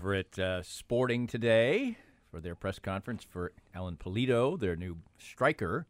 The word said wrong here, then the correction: Alan: Was it? – It's Alon. – Alon Pulido. – I believe it's pronounced Alon.